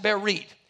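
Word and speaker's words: berit (ברית): This is the word berit. It's pronounced berit.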